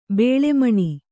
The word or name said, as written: ಬೇಳೆಮಣಿ